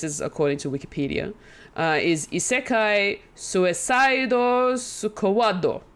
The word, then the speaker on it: Isekai: Isekai.